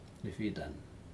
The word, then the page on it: nefitan: Aneityum